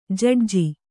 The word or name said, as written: ಜಡ್ಜಿ